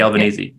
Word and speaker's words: Albanese: Anthony Albanese.